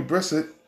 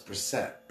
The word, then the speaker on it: Brissett: That was before Jacoby Brissett showed his injury proneness. – You're saying everybody's name wrong. It's Brissett.